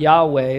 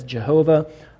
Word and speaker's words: Yahweh: It's also been mispronounced and said as Jehovah.